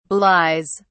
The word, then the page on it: belies: British English